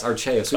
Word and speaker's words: Arceus: I think it's Arceus? – Oh, yes, Arceus. We've been saying it wrong the whole time.